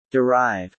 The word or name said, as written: derive